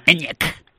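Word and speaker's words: Enyaq: Y en este caso, ENIAC parece que se escribe normal. No, lo han complicado tela, ¿no? – Sí. ¿Verdad? Con Y y con Q al final. – Y con Q al final. ENIAC.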